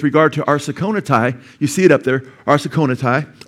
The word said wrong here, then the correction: arsenokoitai: or I'm sorry, arsenokoitai, okay? Arsenokoitai, get to pronounce that right.